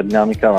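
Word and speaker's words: Dynamica: Ok, allora, per prima cosa puntualizzo solo una cosa sul nome, nel senso che sì, si pronuncia Dinamica, ma la prima è una Y, quindi D-Y-Namica, perché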